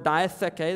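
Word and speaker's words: diatheke (διαθήκη): The word covenant in the Greek is the word diatheke. That's how you pronounce that word.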